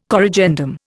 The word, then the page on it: corrigendum: Indian